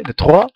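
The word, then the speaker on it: De Treaux: De Treux, se escribe, de Truro.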